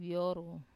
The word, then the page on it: vioru: Wamesa